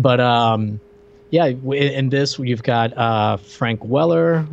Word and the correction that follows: Welker: which uh, what Welker? Why can't you know? We spent like three minutes talking about him before we went mm-hmm. – live, and I mispronounced his name like seven times. You corrected me each time, and I don't respect you.